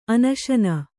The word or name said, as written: ಅನಶನ